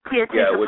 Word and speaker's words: Tia Chucha: You are also co-editor of Power Lines, A Decade of Poetry from Chicago's Guild Complex, all from Tichucha, is that how you pronounce it? – Tia Chucha Press.